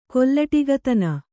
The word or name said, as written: ಕೊಲ್ಲಟಿಗತನ